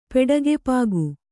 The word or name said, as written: ಪೆಡಗೆಪಾಗು